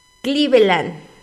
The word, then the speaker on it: Cleveland: Dice para acá el Freiruchi, dice, se pronuncia Cleveland.